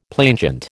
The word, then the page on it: plainchant: American English